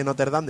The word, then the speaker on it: Notre Dame: Sí, yo, si me das permiso antes de dejarte continuar, te voy a corregir que no sabes pronunciar bien. Es Notre Dame o Notre Dame, ¿vale? Porque mi madre dice Notre Dame de siempre y yo sé que esa es la forma correcta de pronunciarlo.